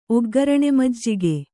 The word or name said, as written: ಒಗ್ಗರಣೆಮಜ್ಜಿಗೆ